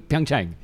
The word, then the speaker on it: Pyeongchang: But one of the coolest things, probably from our perspective, you've done two Olympics, one in Rio and one in Pyeongchang. Pyeongchang? – Yeah. – Pyeongchang. – Pyeongchang. – Pyeongchang. – Oh, he got a little. Oh, that was good. Say that one more time. – Pyeongchang.